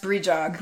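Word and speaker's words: Brídeog: It reads brijog, brijog. – Oh, I saw that. – Yeah, okay. – It's pronounced brijog.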